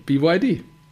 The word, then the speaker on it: BYD: Sagt man dann BIT oder BIT? – Nee, BYD.